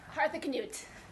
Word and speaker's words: Harthacnut: Harthacnut. – Harthacnut. – Nice and loud. – Harthacnut.